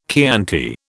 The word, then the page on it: Chianti: British English